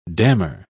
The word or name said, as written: dammer